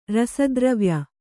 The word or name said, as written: ರಸದ್ರವ್ಯ